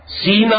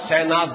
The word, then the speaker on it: سینا: سینا اور سینا دونوں اس کے تلفظ